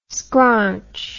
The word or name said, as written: scraunch